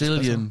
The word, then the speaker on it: Gillian: Gillian Anderson von Akte X, spricht man so aus? Du weißt Gillian. – Es Gillian, okay.